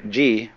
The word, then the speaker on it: ji: The word ji, which is Japanese way of pronouncing the Chinese chur,